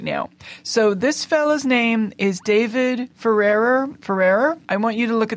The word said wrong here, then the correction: Farrier: David Farrier. – Okay, that sounds like. – F-A-R-R-I-E-R.